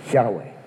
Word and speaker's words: Yahweh: Which was, actually we now know, you know, that because we've got better scholarship than we had then, that those four letters, were, if they were ever pronounced, were pronounced Yahweh.